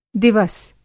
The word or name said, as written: दिवस